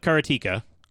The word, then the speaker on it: Karateka: And Fifth Dream says, did just say I can pronounce it Karateka? Yes, that is an improvement. Like, if you watch the making of Karateka, or Karateka, however you want to say it, they actually have a, like, 1-minute video with Jordan Mechner saying you can pronounce it however you want.